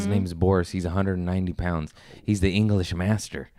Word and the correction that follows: mastiff: Did I say that right? What is he? – An English mastiff.